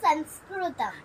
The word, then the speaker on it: Sanskritum: Then I saw this Sanskritum. Actually, I was not able to read that Samarth Sanskritum. Right? Is it? – It's not like in Sanskrit. It's Sanskritum.